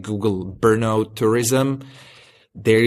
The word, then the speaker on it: Brno: Brno? How's it pronounced? – Brno. It's B-R-N-O. It's actually just a four-letter city.